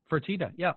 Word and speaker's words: Fertitta: So, the other day I was listening to a podcast, and it was I want to say Tillman Fertitta. – Fertitta, yeah. – Fertitta, thank you.